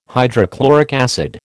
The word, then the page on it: hydrochloric acid: American English